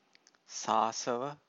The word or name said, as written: sasava